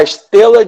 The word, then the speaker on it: Estela: que é a Estela de Hammurabi. É estela e não estrela.